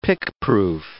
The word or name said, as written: pickproof